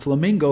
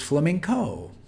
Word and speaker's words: flamenco: So it's not flamingo like the bird, as my family often tells me, says it's not how you say it, it's flamenco. And he's an awesome dancer.